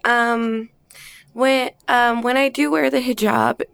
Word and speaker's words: hijab: And you, am I saying this correctly? You wear a hijab? – Hijab in many of your scenes.